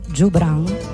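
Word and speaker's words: Joubran: Jobran, anzi, deve essere letto, ho studiato come la pronuncia di questo trio palestinese si debba fare con la G dolce come gelato, quindi Le Trio Jobran.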